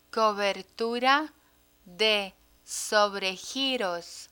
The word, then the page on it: cobertura de sobregiros: spanish